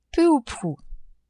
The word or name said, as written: peu ou prou